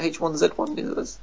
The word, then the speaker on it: H1Z1: Is it H1Z1? – Yeah, that's it.